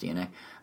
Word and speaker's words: DeNA: Uh, you know D E N A, but pronounced DNA.